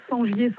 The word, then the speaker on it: Sengier: Sur mon nom, Sangier Sandrine. Et il y a tout. – Sangier, ça s'écrit comment? – Alors, S-E-N-G-I-E-R.